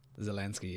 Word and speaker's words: Zelensky: Volodymyr Zelensky. – Zelensky, yeah.